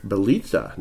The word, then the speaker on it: Bjelica: Okay, the Heat acquire Bielitsa. Nemanja Bielitsa. I can actually pronounce that because he played here. Otherwise, I would have called him Nemanja Bjelica.